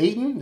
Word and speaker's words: Ayton: Going to the tournament, no suspensions as we record this for either their head coach, Sean Miller, or DeAndre Ayton.